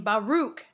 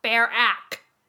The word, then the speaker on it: Baruch: He wrote that early 20th century Wall Street investor Bernard pronounced his last name Baruch, not Barack.